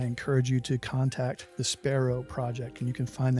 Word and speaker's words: Spero: Sparrow as in the Latin word for hope. Not sparrow as in the bird. So it's spelled S-P-E-R-O.